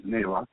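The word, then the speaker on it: NAELA: And Brandon's firm takes care of those things based on those, the short for the, how do you pronounce the acronym is NALA.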